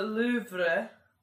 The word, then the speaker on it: Louvre: And well, I was at the Louvre. – Oh God! – That is the correct pronunciation.